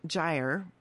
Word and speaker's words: gyre: One was G-Y-R-E, Gyre. I always say that wrong. Mm-hmm. Am I saying that correctly? Gyre? – I think so, yeah.